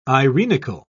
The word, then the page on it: irenical: British English